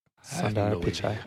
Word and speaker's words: Sundar Pichai: If you were Sundar Pichai, did I pronounce that right? He's the head of Android. – Sundar Pichai.